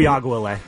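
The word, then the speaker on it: Uiagalelei: Is DJ Uyagalele? I've always pronounced it. Uyagalele. – Yeah. – Of course. Yeah, duh.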